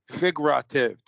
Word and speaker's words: figurative: That's what figura. Figura. Figurative. Three syllables.